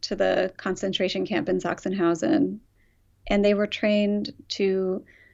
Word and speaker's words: Sachsenhausen: And then there's this whole backstory about how the cows are from, forgive the pronunciation, is it Sachsenhausen? – Sachsenhausen